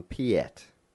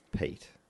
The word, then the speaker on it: Piet: A little while ago, we were calling someone Piet. – Mm-hmm. – I found out that it's Pete.